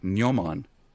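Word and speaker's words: gnomon: Nyoman, spelled with a G, G-N-O-M-O-N, which is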